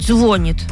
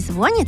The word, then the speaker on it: звонит: Надо говорить, не звонит, а звонит.